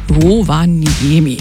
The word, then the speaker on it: Rovaniemi: Wie spricht man es richtig finnisch aus? Rovaniemi.